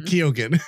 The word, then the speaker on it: Keoghan: Keoghan.